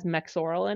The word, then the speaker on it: Mexoryl: So my favorite sunscreen is La Roche-Posay, the one that's made in either like the EU or Canada, and it has Mexoral in it. And that's spelled Mexoryl.